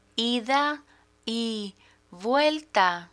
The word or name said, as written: ida y vuelta